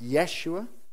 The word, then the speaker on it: Yeshua: Jesus is the Greek name for the name Joshua, or it would be pronounced Yeshua.